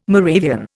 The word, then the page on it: Moravian: Indian